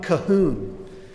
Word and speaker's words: Colquhoun: John Cahoon, spelled like Colquhoun.